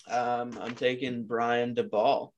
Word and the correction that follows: Daboll: Hope I said that right. DeBall. Yeah. What? – Brian Daboll. – Daboll. Dude, it's spelled D-A-B-O-L-L. There's no Daybell. It's not, where the hell did that come from?